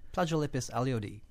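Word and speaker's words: Plagiolepis alluaudi: First of all, did I say that right? Is it Plagiolepis aluati? How do you say it? – Plagiolepis aluati. – Okay, good.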